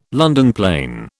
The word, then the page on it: London plane: British English